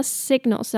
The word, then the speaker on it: signals: 这边的 signals 要注意哦，这个 G 是要发音的哦 signals.